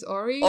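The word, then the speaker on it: Awry: The last single I wanted to share is the comeback by Milgwom, who released the single Ari. I don't know how to pronounce it. – I think it's Ori. – Ori? – Ori. – Ari? – I think it's Ori. – It's A-W-R-Y.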